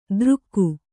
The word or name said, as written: ದ್ರುಕ್ಕು